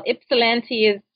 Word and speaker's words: Ypsilanti: Did I say it right? Ypsilanti? – You did very well. Ypsilanti it's a beautiful little town.